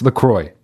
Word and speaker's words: LaCroix: All you motherfuckers who say that you like LaCroix better, and it is pronounced LaCroix. It's not LaCroix. It's not LaCroix. It's not LaCroix. It's LaCroix. I went to the fucking website and I learned how to say it.